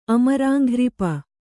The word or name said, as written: ಅಮರಾಂಘ್ರಿಪ